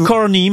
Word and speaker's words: Carney: On dit Carney, mais oui.